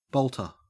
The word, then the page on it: bolter: Australian English